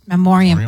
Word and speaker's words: Memoriam: the In Memoriam? – Memoriam. – I always say that wrong.